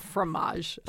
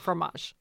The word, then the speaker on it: fromage: Formage. I love the way you say it. So Anglophile. Fromage.